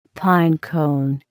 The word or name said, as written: pine cone